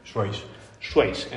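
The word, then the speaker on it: Swace: Estamos conociendo el Suzuki Swayze.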